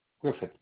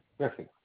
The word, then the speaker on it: Griffith: Yes, my name is John Griffith. – Hello. You said Griffith? – Griffith. Griffith. John Griffith. – Can you spell it? – G-R-I-F-F-I-T-H.